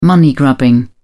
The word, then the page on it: money grubbing: British English